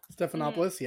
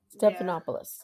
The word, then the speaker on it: Stephanopoulos: The one with George Stephanopoulos. Is that how you say his name? – Stephanopoulos, yeah. – Stephanopoulos.